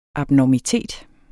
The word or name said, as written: abnormitet